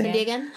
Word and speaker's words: San Diegan: She's a San Diegoan. How do you say? – San Diegan. – San Diegan. Yeah.